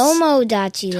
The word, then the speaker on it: Tomodachi: That's Tomodachi Life.